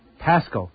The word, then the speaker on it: Paschal: P-A-S-C-H-A-L. – That's it, that's the one. Paschal. How's it pronounced? – Pascal. – So the H really even shouldn't be there.